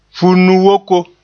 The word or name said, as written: funu-yooko